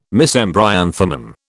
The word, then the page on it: Mesembryanthemum: British English